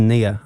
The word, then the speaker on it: Nia: Boys in Blue by Nina Archives is your final song for us tonight. – It's actually pronounced Nia, I'm afraid.